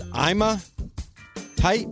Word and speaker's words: Ima Tight: Was landing the role as Ima Tight. Am I saying that right? – Yep, you're saying it right.